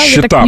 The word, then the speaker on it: щита: Щита. Правильное ударение. Щита.